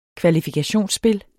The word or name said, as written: kvalifikationsspil